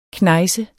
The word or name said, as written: knejse